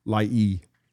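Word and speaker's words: Lai'i (Lāʻie): Is that how you pronounce it? – Lai'i. Mm-hmm.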